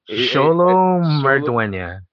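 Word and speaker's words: Xolo Maridueña: Sholo Mardwenya.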